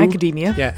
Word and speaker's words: Academia: Academia. – Yeah, there you go. – Couldn't pronounce. I thought you cut out. Academia, yes. – Oh, no. It's like the cookie macadamia.